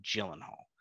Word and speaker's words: Gyllenhaal: Is it Gyllenhaal or Gyllenhaal? – Gyllenhaal. – Okay. Yeah. I just want to make sure that I'm saying it right. Jake. – No hard G.